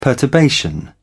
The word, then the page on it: perturbation: British English